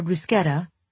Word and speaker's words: bruschetta: the Gruyere bruschetta, the CH in bruschetta is actually pronounced like a K, Mom said knowledgeably, even though I was the one who taught her that after I studied abroad in Rome my junior year.